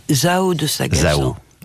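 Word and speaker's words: Zaho de Sagazan: Zao de Sagazan.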